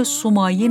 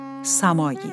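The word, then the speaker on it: سماعی: نام این هاوا در اصل سومایی نبوده و احتمالاً تلفظ درست آن سمایی است.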